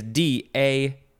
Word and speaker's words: DA: So I guess in certain contexts, you would abbreviate just that part of it to just DA. Four letters to two letters. Ooh-wee! Next is DA. That's how it's pronounced, all caps, first form, noun from 1951.